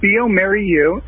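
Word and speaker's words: bioMérieux: It's pronounced Biomerieux, and it's Biomerieux.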